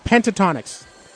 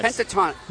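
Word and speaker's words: Pentatonix: The Pentatonics. Pentatonics. – Pentatonics.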